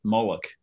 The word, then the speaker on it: Molech: Yeah, actually, I think you mentioned it earlier, the cult of Molech. Other people would pronounce it Moloch, I guess.